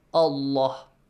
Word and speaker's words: الله: So the letter lam in the word Allah is pronounced heavily since you have a dhamma before.